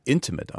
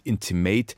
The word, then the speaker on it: Intimate: Intimate heißt ihre neue Serie. Und ehe Sie nun einschreiten und sagen, Moment, das englische Wort, das der Serie den Titel gibt, spricht man doch intimate aus, nicht intimate.